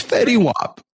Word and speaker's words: Fetty Wap: How do I say it? – It's Fetty Wap.